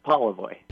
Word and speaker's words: Polevoy: You were close. It's Polevoy. – Polevoy. Okay. I just had the accent on the wrong syllable.